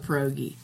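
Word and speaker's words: pieróg: See, the Polish version of it is spelled P-I-E-R-O-G. – Okay. – And the plural is with an I at the end. No I-E. No, so it's like pierog, if you want one, and it's pierogi for more than one in Polish.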